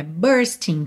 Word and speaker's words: bursting: Então, olha, bursting com with. Como você não pronuncia a letra G, você vai juntar pelo aquele som de NH.